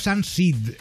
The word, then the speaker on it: Sunseed: Tú eres coordinadora de vida sostenible del proyecto SunSeed.